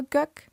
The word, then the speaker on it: Gök: Der wird auch eigentlich Gök ausgesprochen. Also genauso wie eigentlich im Deutschen.